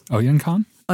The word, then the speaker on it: Oyinkan: O-Y-I-N-K-A-N. How should we say that? – Oyinkan? – Oyinkan Braithwaite.